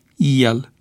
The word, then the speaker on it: el: Astfel se rostesc cu IE, la inițială, pronumele personale: eu, el, ei, ele și formele verbului a fi: ești, erați, e, eram, erai, era, erați, erau. Greșeala de pronunțare cu E deschis în loc de IE se datorează probabil unor tendințe de hipercorectitudine,